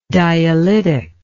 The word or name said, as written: dialytically